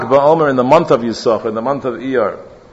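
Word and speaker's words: Yisachar (יששכר): That's why in the word Yisachar you have a silent sin and a pronounced sin. The way Yisachar is supposed to be pronounced, correct? It's not Yisachar, like some say, because there is no Nakuda under the second sin. Alpidiktuk, it's supposed to be Yisachar.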